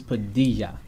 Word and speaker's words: Padilla: All right, your last name is Padilla.